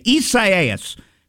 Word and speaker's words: Isaias: That's how you say it. I guess I was saying it wrong yesterday. Isaias.